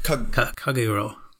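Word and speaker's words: Kagerou: They didn't play Kuragu. Is that how you pronounce it? I forgot already. – Kagero. – Kagero, okay. Not Tattoo. Kagero, okay. Yeah.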